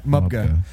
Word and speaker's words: Mubga: Mubga. – Do you like Mubga? – Maybe. – Think about it. Mubga. – Actually, no. Hold on. – M-U-B-G-A.